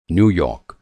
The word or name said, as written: न्यू यॉर्क